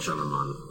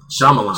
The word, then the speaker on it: Shyamalan: Night Shyamalan. – First of all, it's M. Night Shyamalan. – Shyamalan. – So if you're going to disparage somebody, please say the name right.